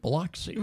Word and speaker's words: Biloxi: And it's the base was in B-I-L-O-X-I, which looks like Biloxi. – Right, right. – But it's Biloxi is how they pronounce it there.